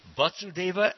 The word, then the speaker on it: Vasudeva: Vasudeva, like Vas, like bus or rust.